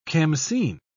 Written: khamsin

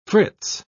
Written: fritz

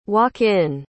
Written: walk-in

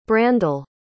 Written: brandle

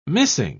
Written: missing

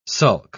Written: sulk

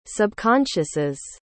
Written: subconsciouses

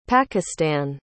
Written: pakistan

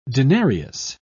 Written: denarius

